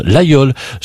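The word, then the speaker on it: Laguiole: Ça s'écrit l'Aïeul, mais on prononce l'Aïeul.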